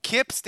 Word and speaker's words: Kyps: Kips, did I say your name correctly? – Yeah, that's correct, Kips.